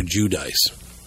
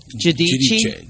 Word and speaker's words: Giudice: Most people call her Giudice. – Ugh. I don't know. – Giudice, okay? Giudice. – And the true pronunciation is Giudice.